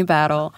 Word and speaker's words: Battle: When I said it, immediately I was like, is it Batal? I don't know. – No, it is Sydney Battle.